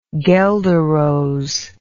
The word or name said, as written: guelder rose